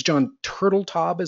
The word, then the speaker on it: Turteltaub: Just to dial everyone in, this is director John, I think it's John Turteltaub is how you say his name.